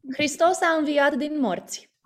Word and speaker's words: Hristos a înviat din morți: Hristos a înviat din morți.